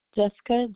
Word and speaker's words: Jessica: Jessica, J-E-S-S-I-C-A. My last name is L-U-D-E-K-E.